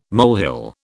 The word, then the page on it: molehill: British English